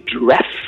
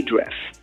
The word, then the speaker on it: Dreff: After Leslie Taylor and Mark Zubak, we've got Jay Dreff, D-R-E-F, that's how he pronounces his, calls himself, Jay Dreff.